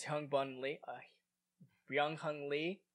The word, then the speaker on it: Byung-Hun Lee: I love, by the way, because Byung-Hun Lee, that's how you pronounce his name.